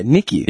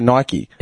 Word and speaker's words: Nike: Yeah, Nike. – Yeah. – And we say it wrong. In America, they say Nike.